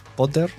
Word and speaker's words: Podder: Y por suerte han venido eh, a nuestro rescate empresas como la que tenemos hoy, eh, Podder, que se pronuncia así, ¿no? Potter. Sí, sí, sí, Potter. – Potter, con 2D